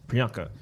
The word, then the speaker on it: Priyanka: Priyanka.